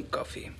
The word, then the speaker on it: Coffey: ¿Te llamas John Coffey? – Sí, señor jefe. Como el café. Pero se escribe diferente.